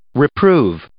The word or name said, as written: reprove